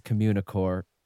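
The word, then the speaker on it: Communicorp: The parent company is Communicore, with a P, but they don't pronounce the P.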